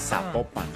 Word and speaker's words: Zapopan: Oiga, nos escriben los oyentes y que no se pronuncia Zapopan, sino que se pronuncia Zapopan. – Ah, Zapopan.